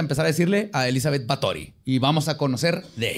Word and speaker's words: Báthory: Pues de hecho, investigando este caso, descubrí que se pronuncia Batori. Y así le voy a decir porque está padre. Batori.